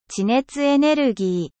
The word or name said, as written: energie geothermique